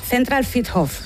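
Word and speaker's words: Zentralfriedhof: Que no lo he dicho bien. – Zentral Friedhof, creo que se dice o algo así. – Ahí te han faltado letras. – Es que luego yo me lo pongo, ¿cómo se dice fonéticamente? – Central Friedhof.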